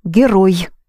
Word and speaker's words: герой: Hero is герой. The H, the H becomes the G. Герой. Short E in the end.